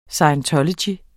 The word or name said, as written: SCIENTOLOGY